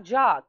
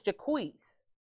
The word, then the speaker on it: Jacquees: And it's not Jacques, because he's black. It's Jacquees. – Oh, shit. Sorry. – Yeah, ooh. They named the baby Jacquees.